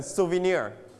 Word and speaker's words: souvenir: Your turn, souvenir. – Souvenir. – This one, ครับ Stress on the last syllable, souvenir.